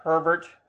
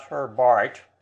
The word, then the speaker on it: Herbert: I checked the pronunciation with two or three Britishers, and it's Herbert, not Herbart, as I had previously believed for 60 years almost.